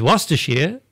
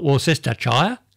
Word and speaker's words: Worcestershire: Worcestershire is Worcestershire, not Worcestershire, as I say.